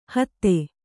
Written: ಹಾದಿ

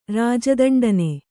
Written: ರಾಜದಂಡನೆ

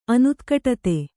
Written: ಅನುತ್ಕಟತೆ

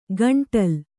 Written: ಗಂಟಲ್

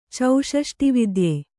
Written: ಚೌಷಷ್ಟಿವಿದ್ಯೆ

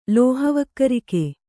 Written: ಲೋಹವಕ್ಕರಿಕೆ

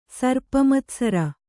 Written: ಸರ್ಪಮತ್ಸರ

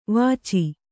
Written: ವೀಚು

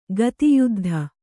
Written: ಗದಾಯುದ್ಧ

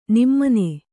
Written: ನಮನ

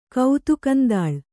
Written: ಕೌತುಕಂದಾಳ್

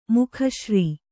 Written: ಮೇಗುಸಿರ್

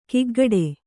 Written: ಕಿಗ್ಗಡೆ

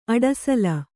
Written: ಅಡಸಲ

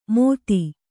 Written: ಮೋದಿ

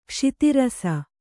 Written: ಕ್ಷಿತಿರಸ

